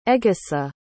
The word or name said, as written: egesa